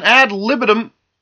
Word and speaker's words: ad libitum: Heck, even when you compare a strict calorie-counting diet with an ad libitum...